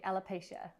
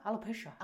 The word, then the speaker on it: alopecia: Something we were going to discuss today was, am I saying it correctly? Alopecia. – Yes.